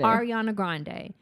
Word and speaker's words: Ariana Grande: Ariana Grande.